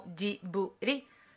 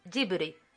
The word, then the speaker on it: Ghibli: internacionalmente lo pronuncian Ghibli y en japonés lo pronunciarían como Jiburi, que si lo dice rápido es un Jiburi. Suena parecido a Ghibli, pero como no tienen el sonido BL, pues hacen Jiburi.